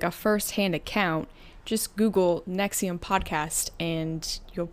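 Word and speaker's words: NXIVM: Oh, it's Nexium.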